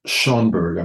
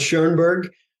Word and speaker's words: Schoenberg: I'm not sure if I'm pronouncing that correctly. And you describe him as an apocalyptic prophet. What was it about Schoenberg that was so prophetic? – Yes, and you got the name right on the nose there. Arnold Schoenberg,